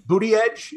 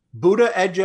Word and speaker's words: Buttigieg: The problem is I've seen at least three or four different columnists try to lay out the right way to pronounce Mayor Pete's name, and each one of them is different. Booty Edge is one. Buddha Edge Edge. Buddha Edge. Oh, so I think it's Boot Edge Edge.